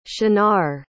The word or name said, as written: shunnar